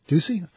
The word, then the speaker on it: Ducey: Republican Doug Ducey, how do you say that name? – Ducey.